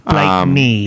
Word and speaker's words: Blakeney: They wanted to hear the Blakey twins, who, when they first went on to Neighbours, Is it Blakey or Blakeney? – Oh, I don't know. – I think it's Blakeney. – It's spelled Blakeney. Blakeney? – Blakeney.